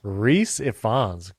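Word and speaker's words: Rhys Ifans: Reese Ifans.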